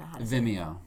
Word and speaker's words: Vimeo: Okay, so I know that when we first started speaking, you sent me a video of, Vimeo.